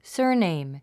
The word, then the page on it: surname: sə́ː r nèim; サーネイム